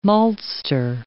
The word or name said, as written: maltster